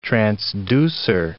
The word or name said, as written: transducer